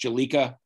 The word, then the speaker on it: Bjelica: I think it's pronounced Jelica, B-J-E-L-I-C-A from the Kings, who isn't playing much, who shoots the ball very well, who the Sixers thought they had signed 3 years ago.